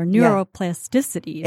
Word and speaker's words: neuroplasticity: I'll throw a term out there, neuroplasticity. – Yeah. Absolutely. – Is the correct pronunciation. – It sure is.